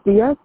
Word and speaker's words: Diaz: My name is Diaz. – Can you spell it? – D-I-A-Z. D-E-N-I-S-S-E. – D-E-N-I-S-S-E.